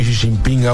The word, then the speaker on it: Xi Jinping: Xi Jinping ahora por la inteligencia. – Xi Jinping, pero good pronunciation.